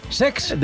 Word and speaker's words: Cesc: No lo he dicho mal, es Sex. – De Chex. De Hex Fàbregas, que se estrenaba en la competición, ganó 4-1 al Leipzig en otro partidazo.